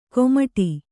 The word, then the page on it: ಕೋಮಟಿ: kōmaṭi